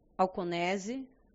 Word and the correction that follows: Anconese: falei certo? Anconese, sejam bem-vindos.